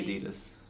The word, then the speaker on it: Adidas: So how do you pronounce A-D-I-D-A-S? 1, 2, 3, go. – Adidas. – That was the same.